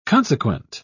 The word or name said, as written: consequent